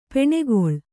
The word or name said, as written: ಪೆಣ್ಗೂಳಿ